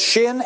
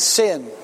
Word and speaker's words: Shin: Actually, it's pronounced in two ways. It's Shin and Sin.